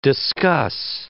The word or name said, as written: discuss